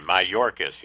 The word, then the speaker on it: Mayorkas: Mayorkas, yeah. – It rhymes with Dorcas.